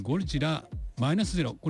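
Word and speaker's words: ゴジラ-0.0: ゴジラのシリーズ最新作ゴジラマイナスゼロ、これゴジラマイナス 0.0 って書いてゴジラマイナスゼロって読ませるんですけども、日本では 11 月 3 日公開の発表になってます。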